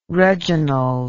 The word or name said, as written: reginald